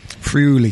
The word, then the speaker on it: Friuli: Friuli.